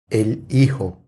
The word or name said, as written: El hijo